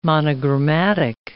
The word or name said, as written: monogrammatic